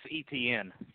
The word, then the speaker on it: Etienne: Running back for Clemson, Travis Yente. He posts the absurd monster numbers. Did I mispronounce his last name? – Yes, Travis Etienne.